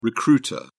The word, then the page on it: recruiter: British English